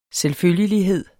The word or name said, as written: selvfølgelighed